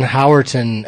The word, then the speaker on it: Howerton: I listened to the Glenn Howerton episode.